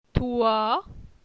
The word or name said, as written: Toi